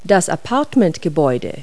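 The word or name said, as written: apartment building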